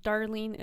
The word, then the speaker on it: Darleen: It's Darlene. It's spelled Darleen, middle initial H, Palmer, Palmer.